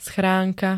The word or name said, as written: schránka